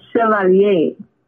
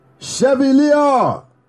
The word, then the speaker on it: Chevalier: No, it's not pronounced how it looks. It's French. – Let me hear you say it one more time. – Chevalier. – So the V, you're not even saying the V.